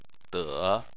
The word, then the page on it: de: 3